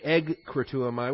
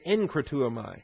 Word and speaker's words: enkratuamai: And why we pronounce it as an N instead of a G, because it comes immediately in front of the kappa. So we don't say egkratuamai, we say enkratuamai.